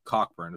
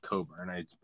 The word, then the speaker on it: Cockburn: I mean, his name's Cockburn. It's pronounced Coburn, but it's Cockburn.